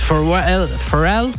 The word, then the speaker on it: Pharrell: fact about this fella for this album was Pharrell Williams,